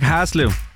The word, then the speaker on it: Haslev: Og vi skal byde velkommen til dagens deltager fra Haslev. Hej med dig. – Hej, Anton. – Udtalte jeg det rigtigt nok, Haslev? – Hej, Haslev. Ja, okay.